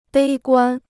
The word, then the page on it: 悲观: bēi guān